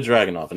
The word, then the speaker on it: Dragunov: Walter versus how you say his name? Dragoon. I cannot do it. Ilya Dragunov.